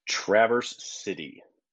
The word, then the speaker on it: Traverse City: Traverse City. – Traverse. You say Traverse City. Okay. – Yes. – I have always said it incorrectly. I've always said Traverse City.